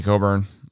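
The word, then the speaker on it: Cockburn: Kofi Coburn, which I can't believe that's how you pronounce his last name when I looked at it.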